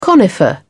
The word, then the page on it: conifer: British English